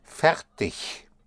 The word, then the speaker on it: fertig: The word for ready ist fertig. Fertig. Fertig. Fertig ist spelled F E R T I G. I told you earlier that I, the IG ending is pronounced like an I-C-H. Ich. Fertig. Usually. Fertig The, the, sound of ver is spelled V-E-R.